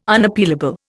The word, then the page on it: Unappealable: Indian